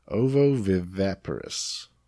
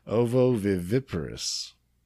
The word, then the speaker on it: ovoviviparous: And the term for that is ovoviviparous. Nope. – Try it again. – Ovoviviparous.